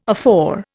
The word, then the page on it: afore: Indian